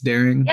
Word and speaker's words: Daering: It's Daring? – Yeah.